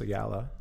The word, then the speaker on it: Ayala: Or just search my name, Ambrose Ayala. A-M-B-R-O-S-E-A-Y-A-L-A. No one knows how to spell it.